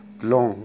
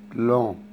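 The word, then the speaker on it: blanc: اللون الثامن لون، ناس كتير بتغلط فيه، لون بينطق السي، السي لا تنطق، بلون والان بتتنطق من الانف، بلون يعني ابيض بس المؤنث بتاعه.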